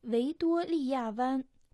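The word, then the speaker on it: Вэйдуолия ван (维多利亚湾): Вэйдуолия ван. Вэйдуолия – это транскрипция имени Виктория с английского языка на китайский. А последний иероглиф «ван» произносится в первом доне.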